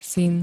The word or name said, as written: syn